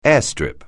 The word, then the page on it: airstrip: English